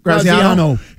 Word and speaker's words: Graziano: Let's ask that question because then, what's his name, Dan Graziani? Is that how you say it? – Graziano.